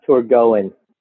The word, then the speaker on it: Torgoen: Uh, and you're right, we do get a bunch of different uh, uh, responses to the name, but the official uh, name is pronounced Torgoen.